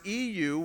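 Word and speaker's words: EU (εὖ): Believe it or not, when we trace the occurrences of this little Greek word, EU, which is pronounced you, throughout the New Testament, there's only four occurrences of it outside of the combination word here in Philippians 4:8.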